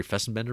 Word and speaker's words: Fessenden: The first one that I always think of is Habit, the Larry Fessenden movie, did I say that right? – Yep.